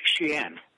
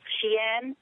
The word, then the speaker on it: Xi'an: Xi'an. – Xi'an. – Xi'an.